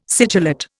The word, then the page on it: sigillate: Indian